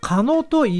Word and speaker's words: 神戸: その名もですね、カノトイワと言います。漢字で書くとですね、神戸。神様の神に、戸田の戸ですね。神戸と書いて、神戸、まさしく神戸、あとゴードというような読み方はね、鉄道好きな方なら知っている駅名なんですが、さすがにカノトと読むことは知りませんでした。